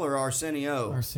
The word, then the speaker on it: Arsenio: How do you say Arsenio? – Arsenio.